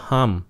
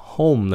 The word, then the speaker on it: home: Đừng có home. Nó là home. Làm như chữ hôn nhân vậy đó. Mà chữ M. Không phải là chữ N.